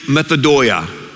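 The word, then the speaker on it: methodoia (μεθοδεία): Literally, the word schemes means, and it's pronounced in the Greek, methodoia, the methods.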